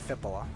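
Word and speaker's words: Filppula: Robbie Fabbri and Fipula Valtteri Fippola.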